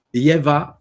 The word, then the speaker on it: Yeva: Il s'écrit, il se dit Yeva 8. Je vous l'appelle Y-E-V-A 8, le chiffre 8. Et il s'écrit encore, je vais vous le répéter, Y-E-V-A 8.